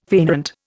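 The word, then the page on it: fainéant: Indian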